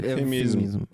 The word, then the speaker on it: эвфемизм: Во-первых, я не могу никак запомнить, как произносится эфемизм. Я говорю то эфемизм, то эфемизм, и я не знаю, как правильно. – Я не могу загуглить. Эвфемизм. Эвфемизм. Эвфемизм.